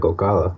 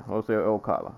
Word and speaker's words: Ocala: Number 2, is it Ocala, Florida? I never know how to pronounce this. – Where? – Ocala, Florida. – Ocala. That's what it sounds like, Ocala. – Yeah, Ocala. We'll say that.